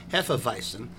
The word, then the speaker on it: Hefeweizen: I know I'm pronouncing it correctly. Hefeweizen, but I'm not sure the exact translation of Hefeweizen.